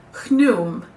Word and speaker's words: Khnum: His name was dedicated to the god Khnum. It's spelled K-H-N-U-M, but I listened to a lot of recordings, and I'm pretty sure it's Khnum.